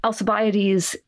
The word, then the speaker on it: Alcibiades: Alcibiades is totally a legit way to pronounce his name when you're talking about him in English.